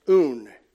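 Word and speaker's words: un (οὖν): Now, that word therefore, if you look in the Greek text, it's actually the second word in the verse, a little word that's pronounced un. Omicron, upsilon, nu, with a smooth breathing mark, which I am being reminded, is pronounced un.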